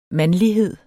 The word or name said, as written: mandlighed